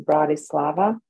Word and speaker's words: Bratislava: It's pronounced Bratislava, Bratislava.